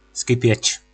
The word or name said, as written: Skipec